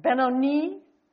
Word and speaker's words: Benoni: Well, she was giving birth to Benjamin, who she called, let's see, I looked up the right way to say it, Benoni, which means son of sorrow.